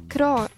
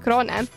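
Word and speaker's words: Crone: Sellepärast, et just Viktor oli ühes intervjuus öelnud, et talle ei meeldi, kui öeldakse Kroon, vaid et peab hääldama nii nagu kirjutatud on, eks siis Kroone.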